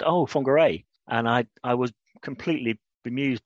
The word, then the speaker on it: Whangarei: Whangarei.